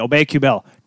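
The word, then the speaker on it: Aubé-Kubel: How about Nicholas Obey-Obel? Or is it, how do you say it again? Kubel. Excuse me, Obey-Kubel.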